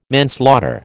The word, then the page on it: manslaughter: American English